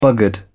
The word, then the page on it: buggered: British English